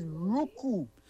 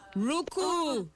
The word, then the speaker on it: Ruku: The pronunciation is Ruku. – Ruku. There we go.